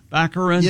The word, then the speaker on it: Baccarin: Daredevil's girlfriend, Morena Baccarin? – Baccarin.